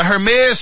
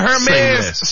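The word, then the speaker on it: Hermès: Yep, Savannah James was on a podcast the other day and she was speaking about her experience at a Hermes store in Italy. – Hermes? – Is that how you, see, I always hit the H. – The H is silent.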